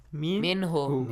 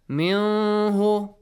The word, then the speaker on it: Min Hu: Min Hu. – Min Hu. Simple as that. Nothing is changed. Yes. Not Min Hu or Mihu or whatever.